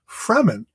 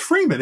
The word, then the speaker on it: Fremen: And they have things in there where they pronounce the tribe Fremen. And it's like, no, it's Freeman. It's Freemen.